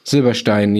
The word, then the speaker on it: Silberstein: Lass uns vorher nochmal über den sogenannten, oder nicht nur sogenannten, den tatsächlich ja so heißenden, aber sehr braunten Herrn Silberstein, wird überhaupt so ausgesprochen, Silberstein? sagen, ja. Reden. – Ja. Silberstein, ja genau.